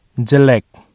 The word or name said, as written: jelek